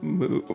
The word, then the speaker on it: Mu: Moo.